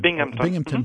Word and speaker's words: Binghamton: Binghamton mm-hmm.